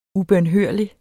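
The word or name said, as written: UBØNHØRLIGT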